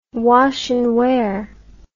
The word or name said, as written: wash n wear